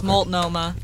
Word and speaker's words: Multnomah: No, it's Multnomah.